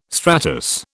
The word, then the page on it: stratus: British English